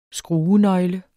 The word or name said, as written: skruenøgle